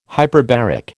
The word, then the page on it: hyperbaric: American English